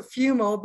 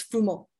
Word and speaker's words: Fumo: It's, it's Fumo. Okay, I didn't want to butcher it. I'm the queen of butchering names. – No, that's okay. – Most so, uh, most English people pronounce it Fumo, but in Italian it's Fumo.